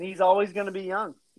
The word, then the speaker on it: Jung: I want Josh Jung. I like that. I like the idea of like having that hard J there to Jung. – Jung. But he's going to be Josh Young, apparently.